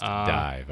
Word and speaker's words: DIIV: Horsehead by, now, is that D-I-I-V? – Yes, pronounced Dive. – Oh, pronounced Dive.